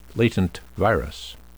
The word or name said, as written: latent virus